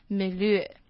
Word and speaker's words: milieu: 那最后来看一个这个最后句子的最后一个字是 milieu。 Milieu 它源自于法文，它是指周遭的环境或者是出生背景，那其实这个字比较罕见啦，那只是教大家就是它怎么念 Milieu 它是法文。